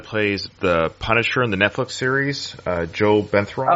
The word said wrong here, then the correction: Bernthal: I can't say his name properly. – Bernthal. – Bernthal.